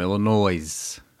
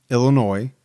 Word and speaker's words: Illinois: And I think in parentheses it said something about there's no, you know, the S is silent or I forget, but I get a kick out of it. – Back in the day when they originally named the state Illinois, It wasn't silent.